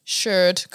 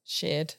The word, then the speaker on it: Sherd: Yes, and she taught us how to pronounce, well, she taught me how to pronounce Sherd correctly, which was great. – How would you? – I probably would have said sheared in the past, which sheared. Is incorrect, yes.